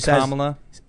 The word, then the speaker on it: Kamala: Now we got Kamala. – Yeah. – Is that it? Okay. – Yeah, she says Kamala. – She says think of the word comma, C-O-M-M-A dash L-A.